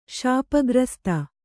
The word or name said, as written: ಶಾಪಗ್ರsಸ್ತೆ